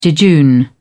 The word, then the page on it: jejune: British English